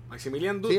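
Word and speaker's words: Maximilian Dood: De Maximilian Dude es que se llama el tipo, ¿verdad? Maximilian Dude, sí, lo dije bien.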